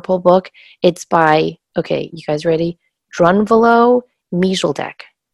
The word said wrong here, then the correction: Melchizedek: And his last name is Melchizedek.